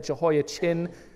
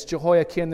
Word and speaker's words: Jehoiakim: I said that wrong last week. I said it Jehoiachin. I don't know why I did that. It's Jehoiakim. There's no ch sound in Hebrew.